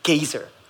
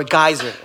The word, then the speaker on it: geyser: There's something that's called geyser or geyser in different pronunciation.